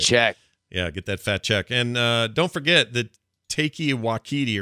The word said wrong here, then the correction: Taika Waititi: or however you say his name. – Taika Waititi.